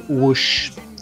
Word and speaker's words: Whoosh: E o próximo álbum também saiu dia 7 e é dos Dinossauros Deep Purple com seu álbum Whoosh. Eu acho que é assim que pronuncia. Isso é contigo. – É, é isso mesmo, é assim mesmo.